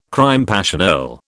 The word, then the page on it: crime passionnel: British English